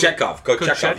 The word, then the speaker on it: Kochetkov: Other guy I want to give a shout-out to, similar to Logan Thompson, goalie, Kochetnov, the Carolina goalie. Sorry, let me not butcher this. – Kochetkov. Yeah.